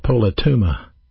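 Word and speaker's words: politouma (πολίτευμα): In the Greek New Testament, the word is pronounced politouma. Politouma.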